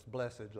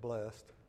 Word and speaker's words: blessed: When we look at that word blessed, and I like to say it with two syllables, blessed, like the Southern Baptists, the old Southern preachers, instead of blessed.